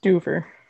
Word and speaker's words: Stuver: how do you pronounce his last name? Is it Stuver? – Stuver. – Yes, Brad Stuver, who apparently Mark Fangmeyer found out he's a big Harry Potter fan.